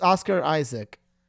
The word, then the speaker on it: Oscar Isaac: Now, he is joking because audience, man, did I have trouble pronouncing his name earlier. Oscar Isaac, Oscar Hayes, Isaac Oscar, I don't know. – Well, one's just wrong, so... – But his name is Oscar Isaac.